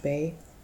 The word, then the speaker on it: Beh: There was a priest who worked at Walla Walla Penitentiary named Father Bay, and the last name is spelled B-E-H. John told me it was pronounced Bay, who held to the truth, but feared the loss of his job in doing so.